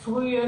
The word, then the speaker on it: frühe: Now, H's close a vowel, but do you pronounce them when they're between two vowels, like frühe and mühe? – You don't. – No? – You do it just like you said, frühe, mühe. It's legato, and you don't say frühe, mühe.